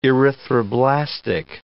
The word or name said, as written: erythroblastic